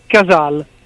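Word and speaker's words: Cazale: Ma io direi Casal. – Casal. – L'ho sentito pronunciare da Robert De Niro una volta mentre raccontava la storia del povero defunto John Casale, della sua breve vita ma intensa a livello di vita come attore, insomma attività professionale. Lui diceva John Casal, quindi penso che sia la pronuncia corretta.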